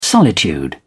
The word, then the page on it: solitude: British English